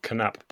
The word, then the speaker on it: Knaap: currently the country manager for NAPS, is that right? – That's right, country manager for Knapp Bikes.